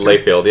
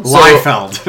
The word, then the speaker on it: Liefeld: Is that how it's pronounced? Layfield? Okay. – Layfield, yeah. He, I kept hearing, because I didn't know. – Layfield? – Liefeld.